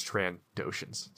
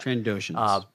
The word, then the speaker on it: Trandoshans: I'm sorry I said it wrong. It's Trandoshans. Sorry about that. – Trandoshans.